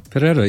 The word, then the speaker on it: Ferreira: Ferreira, yeah. – Oh, you pronounce it better than I do. – She doesn't pronounce it that way. I live in Mexico, so I flip the R.